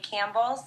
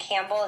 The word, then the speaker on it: Campbell: And my name is Lisa Bellier Campbell. So I can spell it's Lisa, and then Bellier is B as in boy, E-L-Y-E-A. And Campbell is C-A-M-P-B-E-L-L, which is my full name.